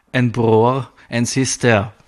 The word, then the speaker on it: en bror, en syster: En bror, en syster. Ist richtig, habe ich richtig ausgesprochen.